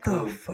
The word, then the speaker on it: Jakub: He plays Jakub, J-A-K-U-B. I think it's Jacob, but I'm going to call it Jakub. – Okay. – And here's why I'm going to call it Jakub. Because it's J-A-K-U-B, last name that I can't pronounce because it's in Czech, from like Czechoslovakia.